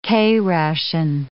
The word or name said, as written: K ration